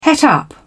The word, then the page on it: het up: British English